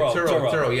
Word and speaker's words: Turo: How do you spell that? – T-U-R-O. – Oh, Toro. Turo.